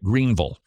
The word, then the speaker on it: Greenville: That's right, Greenville, which that's how you pronounce it. I know it has an I, but it's an ul.